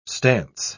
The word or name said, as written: stance